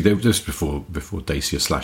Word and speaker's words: Dacia: Because Dacia is how a Romanian person would say it, but you have to allow for sort of sometimes localized pronunciations.